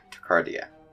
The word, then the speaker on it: tachycardia: Tachycardia.